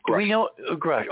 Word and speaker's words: Grush: Steve, do we know whether Grush, is that the pronunciation of his last name? – Yes. – Grush.